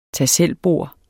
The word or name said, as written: tag